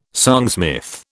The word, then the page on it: songsmith: British English